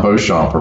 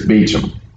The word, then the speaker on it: Beauchamp: And she goes, it's not Beauchamp around here. It's Beecham.